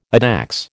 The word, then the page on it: addax: American English